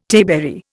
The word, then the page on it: tayberry: Indian